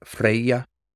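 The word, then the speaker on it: Freyja: Freya would be Freya, almost like a IA at the end, Freya.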